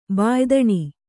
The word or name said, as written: ಬಾಯ್ದಣಿ